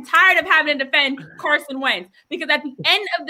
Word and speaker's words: Carson Wentz: That's how you say his name, because there are a number of teams that Carson Wentz could go to right now, this second, this moment, and be the starting quarterback.